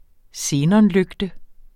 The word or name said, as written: xenonlygte